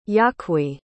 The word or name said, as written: yaqui